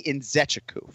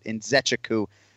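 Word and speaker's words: Nzechukwu: But it's Kennedy Njuku. Final answer. That's what we go with on broadcast. The first time he says it, Njuku. TJ, you were close. You just left out a syllable there, I believe.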